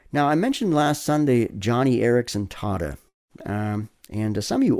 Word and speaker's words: Joni: She spells her name J-O-N-I, but pronounces it Johnny.